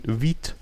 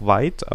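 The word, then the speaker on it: Vite: Und zwar ist da von Viet, manche sagen auch weit, aber äh, es wird französisch ausgesprochen, weil auch der Schöpfer französisch ist.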